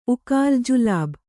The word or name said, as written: ಜಿಲೀಬಿ